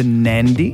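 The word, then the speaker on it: Nadi: Flights out of the Gold Coast to Nandi.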